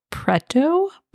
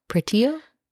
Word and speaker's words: Preto: And this is a special episode because I am reviewing a book called Bonesmith by Nikki Pau Preto? – Question mark. Do you want to spell it? Is it P-R-E-T-O. – Oh, Preto. – Preto. – Pretio? – No.